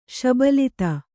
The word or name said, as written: ಶಬಲಿತ